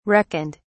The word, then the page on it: reckoned: British English